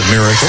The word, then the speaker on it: Mericl: Spells his name M-E-R-I-C-L, but pronounces it Miracle.